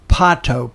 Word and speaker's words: Pato: Chus, C-H-U-S, Pato, P-A-T-O.